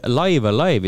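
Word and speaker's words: Live A Live: According to the Nintendo Direct uh,, live alive is pronounced using the second meaning both times, so it rhymes with dive a dive ehk siis Live Alive.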